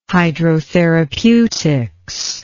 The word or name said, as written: hydrotherapeutics